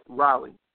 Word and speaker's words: Raleigh: It's Raleigh, okay? Right, not Riley. – Listen. – It's not a children's name. Not R-I-R-A.